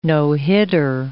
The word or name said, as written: no-hitter